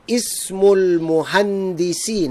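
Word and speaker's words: Ismul Muhandisi (اسم المهندس): Ismul Muhandisi. Now, here, number four, I am pronouncing the Hamza of Ismun because nothing is written before it.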